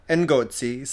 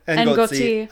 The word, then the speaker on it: Ngozi: It's Chimamanda Ngozi. So you say the N. – Ngozi Adichie. – Adichie, okay. Chimamanda Ngozi Ngozi.